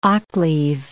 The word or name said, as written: Occleve